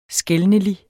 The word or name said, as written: skelnelig